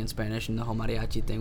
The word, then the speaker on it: mariachi: So how do you pronounce it? Mariachi? – Mariachi.